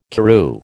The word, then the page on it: Karoo: American English